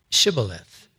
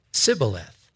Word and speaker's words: Shibboleth: And if he said no, then they would say to him, then say Shibboleth. And he would say, Sibboleth. They just couldn't get the H sound in there. It was just kind of a little speech thing. And he could not pronounce it right.